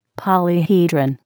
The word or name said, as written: polyhedron